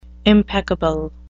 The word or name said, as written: impeccable